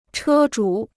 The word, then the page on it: 车主: chē zhǔ